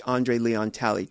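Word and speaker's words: André Leon Talley: I'd met her once before, and during the Q&A, which Andre Leon Talley, did I say it correctly? – Yes.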